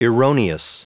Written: erroneous

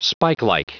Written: spikelike

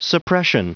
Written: suppression

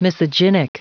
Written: misogynic

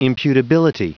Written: imputability

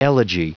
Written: elegy

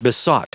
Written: besought

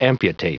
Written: amputate

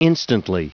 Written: instantly

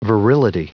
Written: virility

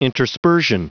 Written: interspersion

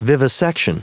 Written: vivisection

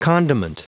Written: condiment

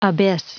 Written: abyss

